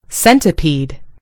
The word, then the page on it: centipede: American English